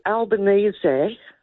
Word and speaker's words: Albanese: If it were Albanese, pronounced correctly.